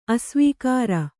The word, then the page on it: ಅಸ್ವೀಕಾರ: Kannada